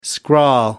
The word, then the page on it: scrawl: American English